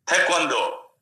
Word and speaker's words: Taekwondo: Taekwondo.